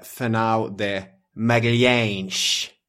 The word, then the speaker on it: Fernão de Magalhães: Det hette han ju inte. – Nej, ja, men det är så man uttalar det ofta när man... – Det är en förenklad version som kommer från angloamerikansk historieskrivning främst. – Och det är bekvämt. – Han hette Fernão de Magalhães. – Mm.